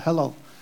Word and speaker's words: Hallel: We know in that time they would sing the, and you're going to have to maybe help me with the pronunciation. Halal? Halal? Yeah, H-A-L-L-E-L. There we go, the halal. Means praise.